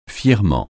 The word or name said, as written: fièrement